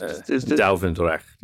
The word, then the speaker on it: Duivendrecht: Duivendrecht, is that pronounced? – Duivendrecht, yeah.